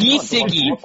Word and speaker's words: Gesicki: I mean, Mike Gesicki third in air yards over the last Giesecke.